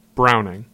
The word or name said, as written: Browning